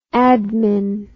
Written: admin

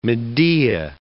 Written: Medea

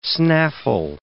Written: snaffle

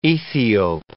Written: Ethiope

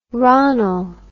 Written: ronnel

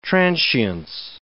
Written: transience

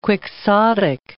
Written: quixotic

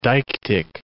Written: deictic

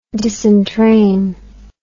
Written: disentrain

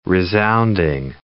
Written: resounding

